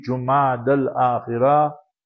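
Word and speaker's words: Jumad al-Akhirah (جمادى الآخرة): There are various ways to pronounce it, but the most eloquent way will be Jumad al-Akhirah.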